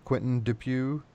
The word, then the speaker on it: Quentin Dupieux: Now, I had seen that you had worked with this director before, Quentin Dupieux.